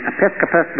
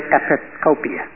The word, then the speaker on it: Episcopius: Some pronounce his name Episcopus, but it's really Episcopius.